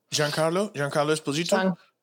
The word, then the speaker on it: Giancarlo Esposito: How do you say that? How do you properly pronounce that? – Giancarlo Esposito. – Oh, I actually kind of got it right.